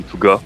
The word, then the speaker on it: Tougas: It's technically correct. If you were to pronounce it with the Quebec accent, it would be Tougas. But, okay. But I suppose that's accurate enough.